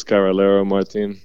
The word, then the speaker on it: Carrillero Martin: Yeah, my name is Jesus Carrillero Martin. Well, I kind of got to roll your R's to pronounce my last name, but you did pretty good.